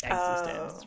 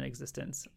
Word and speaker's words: eXistenZ: People say Existenz, and that's how you know they haven't seen it. – I know. Well, that's how I would have pronounced it, so... – It's because, yeah, well, in the movie, Existenz is like a video game, so they call it Existenz rather than Existenz.